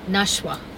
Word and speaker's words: Nashwa: Nashwa.